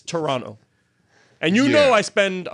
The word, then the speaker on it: Toronto: You don't say the second T. They'll look at you and say, you're not from here. – Well, how would you pronounce it? – It's Toronto, like two N's.